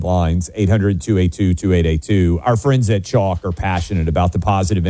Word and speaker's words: Choq: Chalk is spelled C-H-O-Q.